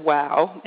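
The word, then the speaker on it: waw: And it's spelled W-A-W-W-A-W, which actually is pronounced wow, and it means yes and Wolof.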